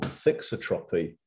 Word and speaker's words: thixotropy: Yeah, well, the actual effect is called, this is a tricky one to pronounce, it's called thixotropy.